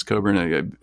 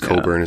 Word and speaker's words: Cockburn: Is his name also spelled like Cockburn? Cockburn. – Like Cockburn. Yes. Yeah. Yeah. Okay. But it's Cockburn.